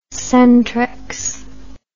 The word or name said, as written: centrex